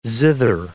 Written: zither

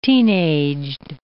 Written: teenaged

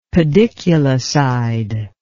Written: pediculicide

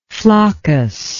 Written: floccus

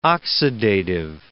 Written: oxidative

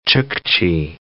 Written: Chukchi

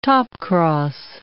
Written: topcross

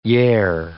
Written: yare

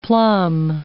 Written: plumb

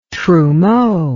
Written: trumeau

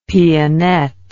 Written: pianette

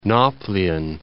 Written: Nauplion

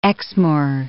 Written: Exmoor

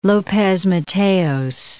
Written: Lopez Mateos

